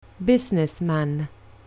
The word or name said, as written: businessman